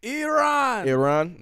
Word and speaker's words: Iran: Is it Iran? Is that how I pronounce it? – Iran.